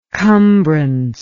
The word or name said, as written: cumbrance